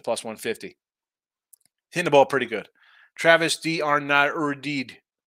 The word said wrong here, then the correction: D'Arnaud: Right? Is that how you pronounce his name? D'Arnaud. Travis D'Arnaud. We have to go French style, like Talion.